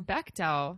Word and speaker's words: Bechdel: I kept hearing it pronounced Bechdel. – Bechdel. – Yeah. – Well, that's how Anita Sarkeesian says that. It.